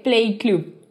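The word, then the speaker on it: Pley Club: ¿Cómo creen que se escribe Play Club?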